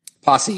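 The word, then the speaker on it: Pasi: And I just want to point out that the author, his name, do you know how to pronounce it? Is it Posse? – Posse, yep.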